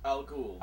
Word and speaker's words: al Ghul: He is Batman, and his Robin is Damian, who is the son of Bruce Wayne and Talia al Ghul, or is that how you pronounce her name? – Al Ghul.